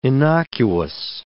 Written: innocuous

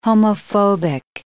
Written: homophobic

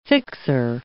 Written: fixer

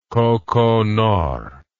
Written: kokonor

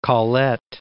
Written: Colette

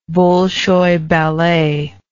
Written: bolshoi ballet

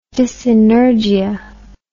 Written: dyssynergia

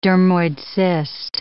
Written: dermoid cyst

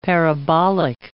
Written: parabolic

